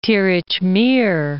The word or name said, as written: Tirich Mir